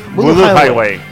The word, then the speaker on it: Voodoo Highway: Voodoo Highway.